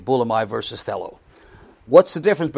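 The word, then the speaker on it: thelo (θέλω): The first two are a noun and a verb, thelo and thelema, G2307 and 2309. And also G1012 and 1014, which is boule and boulamai if you want the Greek words. That's how they're pronounced.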